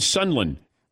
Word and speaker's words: Sunderland: They said, hey, you're saying Sunderland. That's how they pronounce it. – Yeah, the R is casual.